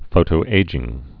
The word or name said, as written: photoaging